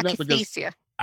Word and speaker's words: akathisia: But this one, and I'm going to pronounce it wrong, so feel free to correct me, but this akathisia, right? Can you explain akathisia.